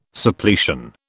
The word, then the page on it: Suppletion: British English